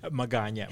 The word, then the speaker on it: Magana: Did I say that right? – Magana. – Magana. Yeah. Okay.